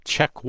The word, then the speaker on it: Czech: The song I'm going to play is called Czech One, but it's spelled C-Z-E-C-H, like Czechoslovakia.